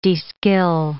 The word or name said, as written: de-skill